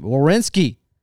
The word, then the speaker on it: Werenski: So starting off with the Calgary game, Werenski. Did I say it right? – Yeah, nailed it.